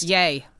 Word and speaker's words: Ye: Ye. Ye or yay? – Yay. Good Lord. – But it's spelled Y-E. – Yes, it is. – What's an apostrophe? – I'm telling you, I've heard even news stories. It's yay.